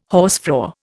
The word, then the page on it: hausfrau: Indian